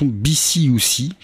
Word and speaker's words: BCUC: Eh bien, c'était donc la formation BCUC, qui s'écrit donc BCUC, mais bon, la première, il faut un peu angliciser ça.